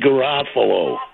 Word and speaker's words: Garoppolo: You guys keep saying it wrong. It's Jimmy Garoppolo.